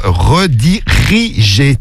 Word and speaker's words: redirigé: Tu as oublié une syllabe. Redirigé. T'as oublié redigé. Non, c'est redirigé sur Snap.